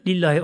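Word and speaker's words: Lillahi (لله): Bu aslında li Allah'ıdır. Burada kısaltılır. Lillahi okunur burada okunuşu.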